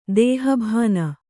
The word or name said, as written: ದೇಹಭಾನ